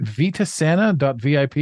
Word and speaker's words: Vitasana: Speaking of which, this episode is a guide getting us to take a look at, am I saying this right? Vitasana.vip. Is that your website? – Yes.